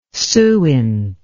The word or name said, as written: suint